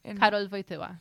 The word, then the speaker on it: Karol Wojtyła: Karol Wojtyła.